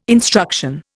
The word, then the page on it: instruction: Indian